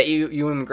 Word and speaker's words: Ewan: Ian McGregor? How do you say his name? – Ewan McGregor. – Yeah, Ewan McGregor.